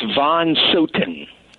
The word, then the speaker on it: Von Soten: John, am I pronouncing your last name correctly? I know it's German. – Yes, it's Von Soten.